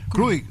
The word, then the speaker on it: Juric: Iván, aquí no nos falta para la pronunciación exacta, tampoco es J-U-R-I-C. No sé si Juric o Kruric, ¿cómo se dice? – Kruric.